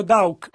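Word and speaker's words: Daugh: Ora, in realtà, nel suo nome, lui è tedesco, D-A-U-G-H, e io lo pronuncio Dauk.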